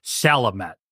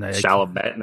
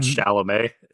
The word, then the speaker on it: Chalamet: And she says it's Timothée Chalamet. – And Chalamet, not Chalamet. – Chalamet.